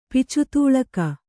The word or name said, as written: ಪಿಚುತೂಳಕ